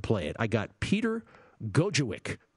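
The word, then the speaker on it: Gojowczyk: The name, it's spelled a lot harder than it's actually pronounced. Gojewicz, which is G-O-J-O. Really, all you have to remember is Gojo. Not Flojo. Gojo. And the last part of his last name is spelled W-C-Z-Y-K. But don't worry about it. It's Gojo. It's Gojewicz.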